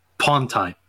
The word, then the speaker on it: upon Tyne: So it's a Newcastle hyphen, U-P-O-N, upon, okay. As in once upon a time. And then another hyphen, and T-Y-N-E is the River Tyne.